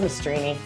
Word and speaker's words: Mastrini: Mastrini. It's spelled wrong. Yeah, from Ellis Island. So it's Mastrini.